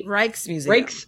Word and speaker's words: Rijksmuseum: The Rijksmuseum.